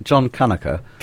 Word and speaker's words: Kanaka: I think I pronounce it John Kanaka. – Kanaka, okay. – Yeah, but when you sing it, it's John Kanakanaka.